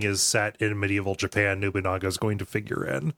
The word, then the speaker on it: Nobunaga: But like one of the cutscenes is you're basically like, you become like a samurai vassal under, was it Nobunaga? – Nobunaga. – Nobunaga. – Pronouncing Nobunaga Nobunga is a running joke on the network.